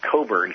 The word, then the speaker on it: Cockburn: It's spelled Cockburn, but it's pronounced Cockburn.